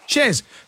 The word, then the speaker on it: Chers: Shers or Shers? – Shers. – Because girls here have written your name as C-H-E-R-S.